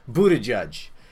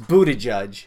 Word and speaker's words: Buttigieg: And I know his name is, how the hell do you say his name? Buttigieg. Yeah, I know it's Buttigieg, but when I first read about this guy,